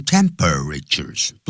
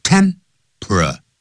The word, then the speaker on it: temperatures: 他说偏低的这个冬天的这种气温啊 temperatures, 千万不要念成 temperatures, 不是四个音节他是念三个音节 temperatures, 这样念的。